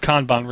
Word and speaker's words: Kanban: And I'm being trolled by Richard Hughes, who says it's pronounced Kanban or Kanban. K-A-H-N-B-A-H-N or C-O-N-B-O-N. Kanban. Kanban. I'll go with Kanban, Richard.